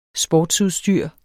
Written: sportsudstyr

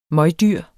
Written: møgdyr

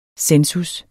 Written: census

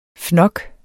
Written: fnok

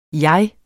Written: min

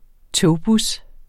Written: togbus

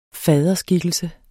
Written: faderskikkelse

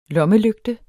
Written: lommelygte

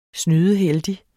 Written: snydeheldig

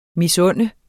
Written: misunde

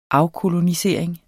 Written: afkolonisering

